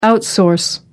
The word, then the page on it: outsource: American English